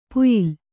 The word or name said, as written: ಪ್ಯಾಲೆ